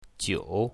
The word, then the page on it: jiu3: mandarin